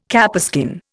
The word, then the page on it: capeskin: Indian